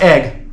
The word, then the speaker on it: Aigle: In the Switzerland porn, they show the city of well, they pronounce it Aigle, A-I-G-L-E, and I've been there, and everyone just, it's A-I-G-L-E, but everyone's like, oh, it's pronounced egg, like scrambled eggs or whatever. They say it's pronounced egg.